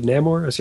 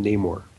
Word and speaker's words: Namor: Do you pronounce it Namor? See, I always pronounce it Namor. – Oh, I have no idea. I've always pronounced it Namor. Namor. – That's okay. – Namor sounds gay more. So I'm going with Namor.